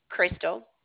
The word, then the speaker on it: Crystal: Crystal.